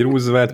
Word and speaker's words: Roosevelt: Roosevelt? Így mondják. – Aha, na, így is mondják, hogy Roosevelt ki, hogy mondja.